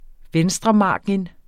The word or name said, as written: venstremargin